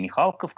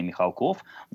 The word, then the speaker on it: Михалков: Сюда же попадает ситуация с известной фамилией Михалков, которая, конечно же, мы на протяжении всего 20 века произносили Михалков, но теперь, насколько я понимаю, некоторые настаивают на том, что нужно вернуться к дворянским корням этой фамилии и произносить ее с ударением на первый слог. Ой, на второй.